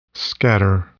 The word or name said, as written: scatter